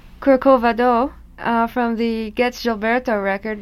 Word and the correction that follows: Corcovado: Did I say that wrong again, Astrid? – Corcovado. Okay. The just the accentuation was in the wrong syllable, that's all.